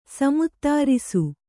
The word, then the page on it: ಸಮುತ್ತಾರಿಸು: samuttārisu